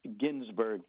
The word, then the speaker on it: Ginsberg: My name is David Ginsberg, G-I-N-S-B-E-R-G.